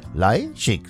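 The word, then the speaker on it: Leischik: Julia Leischig, Leischig, so wird sie ausgesprochen.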